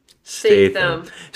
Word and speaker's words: Statham: Jason Statham.